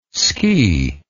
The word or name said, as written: skee